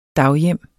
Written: daghjem

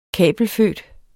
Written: kabelfødt

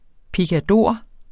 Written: PICADORERNES

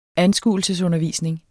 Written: anskuelsesundervisning

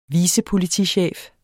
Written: vicepolitichef

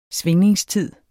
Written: svingningstid

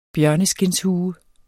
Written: bjørneskindshue

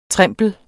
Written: TREMPLEN

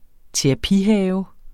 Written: terapihave

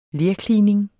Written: lerklining

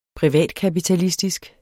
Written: privatkapitalisme

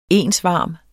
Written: ensvarm

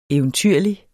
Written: eventyrlig

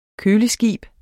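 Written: KØLESKIB

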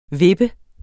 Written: vippe

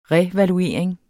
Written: revaluering

